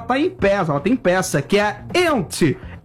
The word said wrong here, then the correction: Yentl: Ente in Concert. – Yentl. – Eu vou fazer com pronúncia Yentl in Concert.